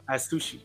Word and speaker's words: Atsushi: Atsushi? How do you say his name? – Yep, Atsushi. Yep, Atsushi Ko.